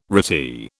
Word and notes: retie: British English

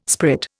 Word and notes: sprit: Indian

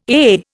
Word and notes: AA: Indian

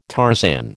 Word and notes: Tarzan: American English

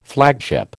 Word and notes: flagship: American English

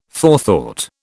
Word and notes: forethought: British English

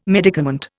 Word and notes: medicament: Indian

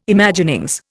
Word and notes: Imaginings: Indian

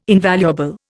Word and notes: invaluable: Indian

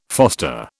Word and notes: foster: British English

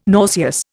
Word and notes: nauseous: Indian

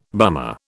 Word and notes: bummer: British English

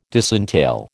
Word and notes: disentail: American English

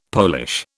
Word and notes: Polish: British English

